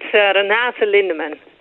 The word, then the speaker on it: Renate Lindemann: I know I butchered your name. How do you say your name? I'm sorry. – Well, it sounded good. It's Renate Lindemann.